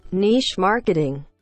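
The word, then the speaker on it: niche marketing: که تلفظ صحیحش میشه نیچ مارکتینگ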